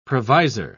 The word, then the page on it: provisor: British English